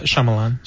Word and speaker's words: Shyamalan: Does anyone know how to say the name? Because I can. – Shyamalan. – Shyamalan? Okay, cool.